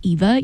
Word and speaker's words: Eva: My name's Eva, E-V-A. Richie is spelled R-I-C-C-I.